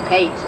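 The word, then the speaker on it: Patz: Eitan Paez, Pates, as some people pronounce it, whose parents have, as the Goshes have, spent endless hours searching for their lost son, and much pain.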